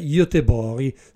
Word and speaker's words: Göteborg: Non ho invece alcun dubbio su dove viva abitualmente Sofia Talvik, perché lei è svedese e la sua città è Göteborg, una pronuncia tutta italiana, perché gli svedesi invece la chiamano, come dovrebbe essere, Göteborg.